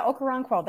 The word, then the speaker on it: Okoronkwo: Ogbonia Okoronkwo. Is that how you say it? – Yeah, Okoronkwo. That's good.